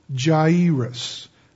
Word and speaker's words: Jairus: Anyway, In those Middle Eastern languages, when you see an A and an I, the A is pronounced like the A in far. It's kind of an ah sound, or the A in ah, A-W-E. And the I is pronounced like an E, like the I in king or sing. So what we have here is, if you say it slow, it's Jairus.